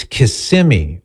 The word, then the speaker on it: Kissimmee: She says, you mispronounce Kissimmee, Florida. Just wanted you to know that I'm listening. – Is that Mary? – That is Angela, as a matter of fact. She says, it's Kissimmee.